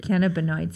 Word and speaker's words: cannabinoids: Cannabinoids. Yeah, if you think of cannabis and then just start with that, think cannabinoids.